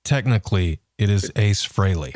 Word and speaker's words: Frehley: Well, that was the whole takeoff on his band's name was Frehley's Comet to kind of rhyme with Haley, I always thought.